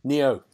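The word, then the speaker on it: Nioh: These PlayStation games, two big PlayStation games, which is Noir, have I said that right? – Neo, and of course Horizon Zero Dawn, which is one of the best PlayStation games.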